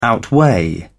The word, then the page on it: outweigh: British English